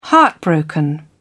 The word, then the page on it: heartbroken: British English